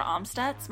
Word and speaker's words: Amstutz: You can find me on all social media platforms at Sandra Amstutz. My last name is spelled A-M-S-T-U-T-Z.